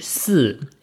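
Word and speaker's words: si (四): Zum Beispiel, man darf nicht zu vierte feiern, weil vier auf Chinesisch si ist genauso dieselbe Aussprache wie Tod.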